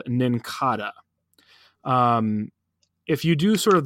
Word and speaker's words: Nincada: But that gets stronger when you introduce, that theory gets stronger when you introduce a little Pokemon called Cincada. Am I saying that right? Nincada. So let me say that again.